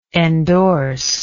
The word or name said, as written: endorsive